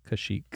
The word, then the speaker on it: Kashyyyk: What is the Wookiee's home world, and how do you pronounce it correctly? – Joe Kashyyyk. – Ooh, is that right? Yes, it is!